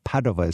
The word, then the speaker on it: Padova: Oh, but Padova is how you say it.